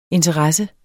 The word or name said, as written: interesse